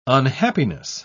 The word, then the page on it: unhappiness: British English